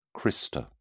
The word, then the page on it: crista: British English